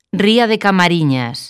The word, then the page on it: Camariñas: galega